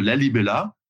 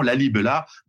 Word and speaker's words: Lalibela: Et c'est à cette dynastie des agouets qu'on doit en particulier le site, le site exceptionnel de Lalibela, comme ça se prononce, hein, ceux qui veulent chercher en même temps, Lalibela, des églises sculptées dans la roche, Hein, et euh, entièrement sculpté dans la roche en 3D.